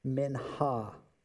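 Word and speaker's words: mincha (מנחה): It is the word mincha. And you really should be able to hawk a loogie somehow in there to pronounce it correctly.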